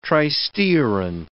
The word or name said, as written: tristearin